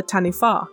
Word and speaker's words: Taniwha: Yes, this week we are looking at the Taniwha. I also must apologise preemptively. I pronounced this very incorrectly last week when I was announcing it, so this is the actual pronunciation in Maori, so just as a heads up.